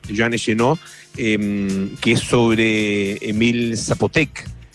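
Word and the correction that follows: Zátopek: Zatopek, perdón, Emil Zatopek, que es maratonista de origen checo, ¿no es cierto?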